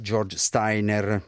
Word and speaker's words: George Steiner: Parliamo, come dicevo, già in copertina dello scrittore e saggista George Steiner. Userò la pronuncia all'inglese, malgrado lui abbia lavorato anche a Ginevra e la famiglia sia di origine austriaca.